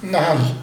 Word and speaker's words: Nahl: Bu Nahl diye mi okunuyor? – Nahl. – Nahl.